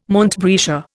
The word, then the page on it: montbretia: Indian